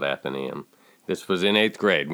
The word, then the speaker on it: Athenaeum: That's right, Athenaeum. It's pronounced Athenaeum.